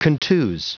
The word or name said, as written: contuse